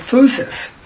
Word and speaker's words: physis: You know, because it begins in Romans, in the Greek, for phusis. – For what? – Phusis is P-H-Y-S-I-S, but pronounced F-O-O-S-I-S.